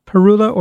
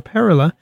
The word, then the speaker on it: Parula: Back at a quarter to the hour by Parula or Parula, depending if you're a birder or not and how you pronounce it, P-A-R-U-L-A.